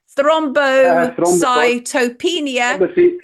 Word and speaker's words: Thrombocytopenia: Thrombocytopenia, thrombosis.